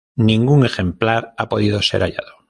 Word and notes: ejemplar: spanisch

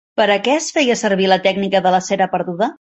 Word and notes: perduda: Catalan

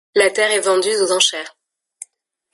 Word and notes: vendue: French

